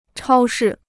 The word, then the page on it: 超市: chāo shì